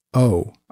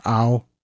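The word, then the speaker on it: Au: Colm O. His last name is spelled A-U. So it's Ow or O.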